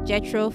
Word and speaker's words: Jethro: Remember, if you ever need someone to talk to, my DM is always open at Jethro for Gram. Jethro is spelled J-E-T-H-R-O for G-R-A-M.